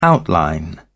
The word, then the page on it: outline: British English